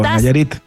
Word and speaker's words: Nayarit: Sí, por Nayarit. – Nayarit. Ah, yo dije Nayarit. Oye, yo pronuncio todo con el acento cambiano. Cambiado.